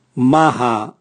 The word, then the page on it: maha: Raga